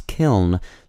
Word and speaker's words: kiln: some English speakers pronounce kiln so that it rhymes with the word mill.